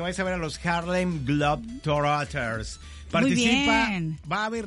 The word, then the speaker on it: Harlem Globetrotters: porque estaremos con los Harlem Globetrotters. O sea, ¿lo dije bien? – Harlem Globetrotters.